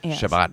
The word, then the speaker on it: Shabbat: Tonight you're going to a Shabbat dinner, which is am I saying it right? – Yeah.